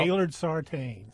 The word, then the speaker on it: Gaylord Sartain: It is not spelled B-I-M-B-O. I looked it up. It's a Cajun spelling. – Yes. – E-A-U-X. – Now, how do you pronounce his real name? The actor. – Well, Gaylord Sartain.